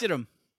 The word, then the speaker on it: Stidham: I don't even know how to say his name. I'm not too familiar Stidham. – With the guy. Stidham. – Silent H. – Yes. – Stidham.